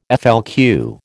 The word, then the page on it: FLQ: American English